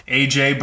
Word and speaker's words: AJ: And then my Instagram for a long time was, it's pronounced AJ, but I spelled everything out phonetically in a total dick move.